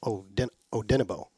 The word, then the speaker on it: Odenigbo: You got the accents on the second syllable, so Odenigbo. – Odenigbo. – And Ifade. The Ifade part's pretty easy. – Yeah. – There's a G in there that you don't pronounce.